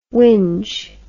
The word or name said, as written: windge